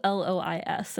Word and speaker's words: Lois: All right, let's now go ahead and learn a little bit about Louise Duncan. I think I can't it, say I, her name. I think it's Lois. It's spelled L O I S, and I'm pretty sure that's just Lois.